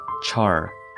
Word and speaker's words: char: Char, pronounced cha in British English,